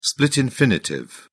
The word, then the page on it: split infinitive: British English